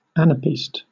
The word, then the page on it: anapest: British English